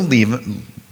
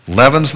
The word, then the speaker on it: leaven: I will try to say leaven, because I understand that's how you're supposed to pronounce it, but I've been saying leaven for a lot of years. Like when we have to go, we're leaving, that kind of thing.